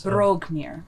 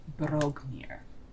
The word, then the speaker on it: Broghmir: Brogmir. – Is that like broke, or is that like brogue? – Brogue. – Like an Irish brogue. – It's spelled B-R-O-G-H-M-I-R. Brogmir.